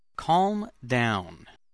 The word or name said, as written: calm down